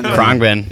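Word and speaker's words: Khruangbin: Yeah, another band we talk about a lot and listen to is, I don't even really know how to pronounce it, Krongben? – Krongben. – Krongben. – Yes.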